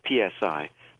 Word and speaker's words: psi: It's spelled PSI, but pronounced psi.